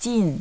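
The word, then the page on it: jin: language Chinese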